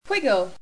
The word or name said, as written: Quiggle